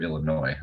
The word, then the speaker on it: Illinois: So, or Illinois, I guess the S is silent.